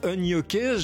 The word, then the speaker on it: Unyoké: Pour la prononciation Unyoké, je sais aussi même ce que ça veut dire, parce que c'est un mot, c'est U-N-Y-O-K-E, qu'est-ce que ça veut bien dire?